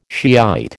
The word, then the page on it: Shiite: American English